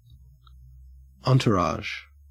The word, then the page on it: entourage: Australian English